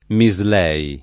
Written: Mislei

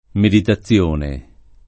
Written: meditazione

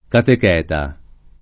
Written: catecheta

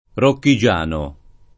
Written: rocchigiano

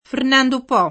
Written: Fernando Po

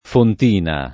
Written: fontina

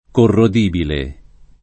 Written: corrodibile

